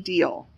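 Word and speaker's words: Dyall: In January 1882, Edgar married again, this time to Jane Deal. I think that's how you pronounce it. It's D-Y-A-L-L.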